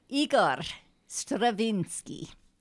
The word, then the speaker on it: Igor Stravinsky: So Igor Stravinsky.